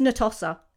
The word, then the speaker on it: Natossa: I keep getting her name wrong. I keep pronouncing it wrong. It's not, I think I emphasize the toss a bit too much. It's just Natossa.